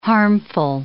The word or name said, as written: harmful